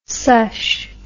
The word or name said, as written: sush